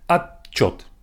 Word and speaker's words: отчет: Отчет means report and it's spelled as at, O-T, and then chot, отчет. Even though the T is not really pronounced, it's more like a pause. Отчет. We don't say отчет. We don't say it like that. It's just simply отчет. Отчет.